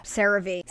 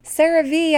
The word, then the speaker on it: CeraVe: And how do you say it? The CeraVe. CeraVe, I was way off.